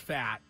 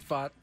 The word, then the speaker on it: Pfaadt: That's a P-F-A-A-D-T. I read it as fat, but he doesn't like to pronounce it that way. – He wants it fought. P-F-A-A-D-T.